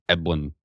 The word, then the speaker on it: Eblen: Am I saying your last name right? Just is it Eblen? Eblen. – You are saying it right. You're like one of the only ones. I always joke that my last name, there's no way to say it well. It's just Eblen.